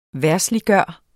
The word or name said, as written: verdsliggøre